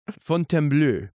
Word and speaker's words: Fontainebleau: I was really hoping to see Guilty Fontainebleau. Am I pronouncing this right? Which I learned this year that you speak French. – Me? But very little. – Moi? – Fontainebleau.